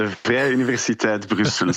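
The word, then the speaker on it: Vrije Universiteit Brussel: Vous êtes glaciologue, professeur de recherche associé au sein du département eau et climat à la, alors, on a discuté ce matin un peu sur la prononciation, à la Vrije Universiteit Bruxelles, c'est juste? – C'est pas mal de Vrije Universiteit Bruxelles. C'est l'université libre de Bruxelles, mais que des néerlandophones.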